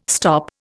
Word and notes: stop: Indian

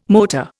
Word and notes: motor: Indian

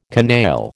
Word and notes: canaille: American English

